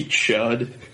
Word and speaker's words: Chud: C-H-U-D, like the movie. – Okay. I was gonna do two D's. – No. – No? – With a silent N. – No, Chud. One D.